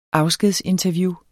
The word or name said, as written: afskedsinterview